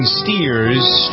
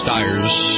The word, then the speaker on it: Stiers: David Ogden Stiers. – Stiers. – Is that how you pronounce it? – Yes. – Stiers.